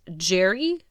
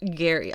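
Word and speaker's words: Gerry: Apparently it is pronounced Jerry, not Gary. I would have guessed Gary because it's a G, but whatever, what do I know?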